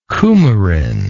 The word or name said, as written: coumaric